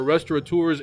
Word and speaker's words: restaurateur: Simon is one of the few who doesn't spell it with that obnoxious N, restaurateur. That is not a thing. It makes no sense. So thank you, Simon, for knowing how that word is said.